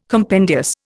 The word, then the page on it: compendious: Indian